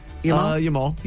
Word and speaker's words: Yamal: Lamine Yamal? – Yamal, yes.